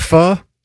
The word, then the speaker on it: pho: So, owner of the lemongrass Vietnamese pho and Thai cuisine. – Oh, you pronounce it pho? – It's pronounced pho. – Even though it's spelled pho? – Yes.